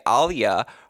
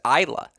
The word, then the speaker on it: Ajla: And you'll say, well, not only do I know her last name is Tomljanovic, you're going to want to say Alia. How you p- correctly pronounce her name is Ajla.